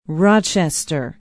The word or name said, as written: Rochester